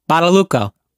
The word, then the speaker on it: Badalucco: Michael Badalucci. How do you say his name? – Badalucco. No.